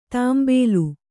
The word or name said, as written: ತಾಂಬೇಲು